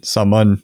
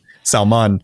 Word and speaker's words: Salman: Salman, I hope I'm saying that right, it's spelled like Salmon, but you know, maybe it's a southern coming out, Salman.